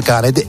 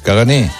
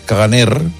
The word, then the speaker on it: caganer: Es que iba a lleva R, cagané, pero no se pronuncia. – ¿Y entonces para qué la ponen? – Bueno, pues pasa con muchos idiomas. Hay muchas letras que van, pero no se pronuncian. No, ¿no? Es cagané. Sí, no. En todo caso sería caganer, vale. Pero la R no se pronuncia, es un cagané.